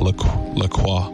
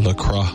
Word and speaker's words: La Croix: Officially, it's pronounced La Croix, so it rhymes with enjoy, not La Croix or La Croix, like the French might say it.